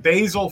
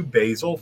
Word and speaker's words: Basile: If you go to Facebook at Basile Fans, go to Basile Fans.